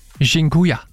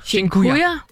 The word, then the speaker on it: Dziękuję: Og en af dem, som jeg har brugt rigtig meget, det er det, der hedder, undskyld min udtale, Dziękuję. – Dziękuję? – Dziękuję.